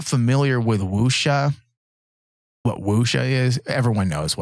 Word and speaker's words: wuxia: She says, we were obviously influenced by samurai films and wuxia films, I believe is how you say that.